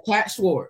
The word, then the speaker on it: Schwarz: Kat Schwarz.